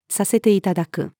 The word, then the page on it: させて頂く: female